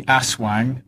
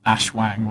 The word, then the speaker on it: aswang: George's tale has at least the benefit of pronouncing the word correctly, aswang, not ashwang, whatever you were talking about.